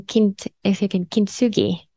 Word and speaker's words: Kintsugi: The Beloved Foundation has this beautiful logo, which I hope I pronounce it right, but comes from Kintsugi, is that right? – Yes, that's right.